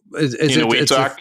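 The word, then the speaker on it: Eniwetok: what's the island base called Entawak? – Inuitok, yeah, that's how you pronounce it.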